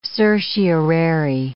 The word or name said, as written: certiorari